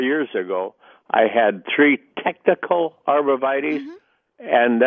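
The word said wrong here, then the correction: Techny: One is techni and the other is hetz midget.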